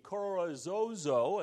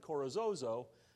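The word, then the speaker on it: Carrizozo: And then on the way back, we went through the Corozozo, and that's exactly how you pronounce it, Corozozo, lava fields.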